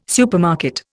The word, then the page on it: supermarket: Indian